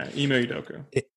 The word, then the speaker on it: Ime Udoka: Ime Udoka, is that how you say his name? – Yeah, Ime Udoka.